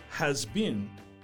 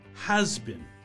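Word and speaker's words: has-been: So its pronunciation is not uh, has been, but has been.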